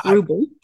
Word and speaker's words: Ruble: You did not. It's Ruble.